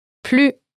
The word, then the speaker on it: plus: In most cases, you pronounce the S sound in plus. – However, in this specific expression, you don't have to.